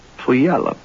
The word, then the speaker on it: Puyallup: Puyallup Valley Daffodil Festival begins today in Orting, Sumner, and Tacoma, and Puyallup. – Puyallup. – Puyallup. You know what? It's, if you pronounce it phonetically, it becomes pay all up.